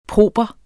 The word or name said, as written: proper